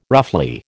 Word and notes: roughly: American English

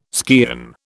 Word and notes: skean: British English

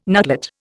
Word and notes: nutlet: Indian

